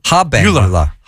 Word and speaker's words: habenula: And one of the things you talked about was the eubenula. Did yes. – I say that right? – Eubenula. Eubenula, yes. – Abenula. – Habenula. – Yeah. Habenula. Okay.